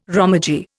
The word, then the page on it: romaji: Indian